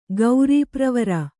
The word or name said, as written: ಗೌರೀಪ್ರವರ